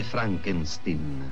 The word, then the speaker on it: Frankenstein: Con Gene Wilder nei panni del dottor Frankenstein. – Si legge Frankenstein.